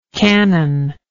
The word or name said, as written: canonlike